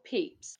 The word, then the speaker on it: Pepys: So firstly, when you said Samuel Peps. Did you mean Samuel Pepys? – Oh, yeah.